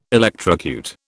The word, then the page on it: electrocute: British English